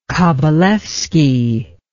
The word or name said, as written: Kabalevsky